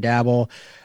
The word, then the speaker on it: Daboll: I know you're covering a lot of them, but Brian Daboll, is it Daboll or is it Daboll? I feel like I always say it wrong. – Daboll. – Okay, it is Brian Daboll.